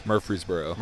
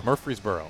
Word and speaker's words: Murfreesboro: Murfreesboro. – Murfreesboro. – Yes. – It's exactly how it looks. – Exactly how it looks. – Okay. When I hear Murfreesboro, I think of David Price.